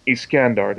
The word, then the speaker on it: Iskandar: It revolved around the story of a multinational teenage crew that journeyed through space to look for the planet Iskandar.